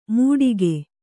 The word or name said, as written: ಮೂಡಿಕೆ